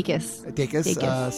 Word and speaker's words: Dacus: This was the Lucy Dacus. Is that how you say her name? – Dacus.